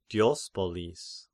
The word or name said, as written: Diospolis